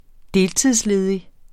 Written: deltidsledig

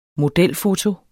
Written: modelfoto

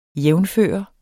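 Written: Jævnførende

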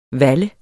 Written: valle

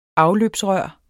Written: afløbsrør

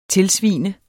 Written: tilsvine

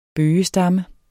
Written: bøgestamme

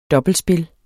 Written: dobbeltspil

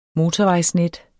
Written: motorvejsnet

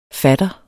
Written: fatter

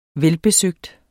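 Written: VELBESØGT

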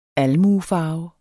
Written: almuefarve

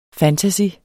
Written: fantasy

